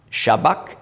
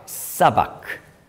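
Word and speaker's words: sabach: Das geht dann als anstatt Shabbak, Sabbak. Und es ist übrigens geschrieben mit einem Chi, Sabbach, also CH, aber im Aramäischen ist das eigentlich ein Q. Das Q spricht man im Aramäischen aus K, K. Und im Altgriechischen hat man das Chi auch K ausgesprochen.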